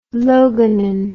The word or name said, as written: loganin